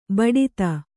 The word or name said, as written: ಬೊಟ್ಟೆತ್ತು